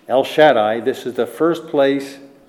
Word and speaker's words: Shaddai: I am El Shaddai, as we would pronounce it. Shaddai in the Hebrew, but I am El Shaddai.